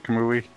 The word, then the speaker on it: Kamui: And in September, we have Kamui, which is that, forgive me if I pronounce, did I pronounce that right, Kamui? – Yeah, that's right.